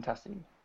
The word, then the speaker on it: Tassie: Yes, Benjamin Tassie.